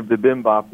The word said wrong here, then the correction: bibimbap: that drove me to call in. – Yeah. Tell me once again what it should be. – Bibimbap. – Bibimbap. I like that much better.